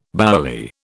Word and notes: bouilli: British English

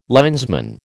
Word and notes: linesman: American English

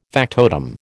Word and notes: factotum: American English

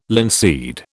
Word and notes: linseed: British English

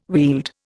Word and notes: Weald: Indian

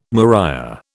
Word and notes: maria: British English

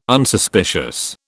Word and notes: Unsuspicious: British English

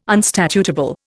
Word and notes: unstatutable: Indian

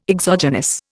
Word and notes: exogenous: Indian

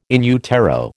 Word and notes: in utero: American English

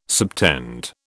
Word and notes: subtend: British English

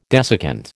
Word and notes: desiccant: American English

